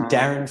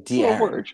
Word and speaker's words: De'Aaron: Just call him De'Aaron Fox. – De'Aaron? – Yeah. – All right, fine. Sorry. I skipped the E apostrophe. – That one's spelled like phonetically too. Like De'Aaron.